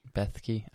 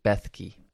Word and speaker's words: Bethke: And him and Jeff Blesky, I think I'm saying Bethke.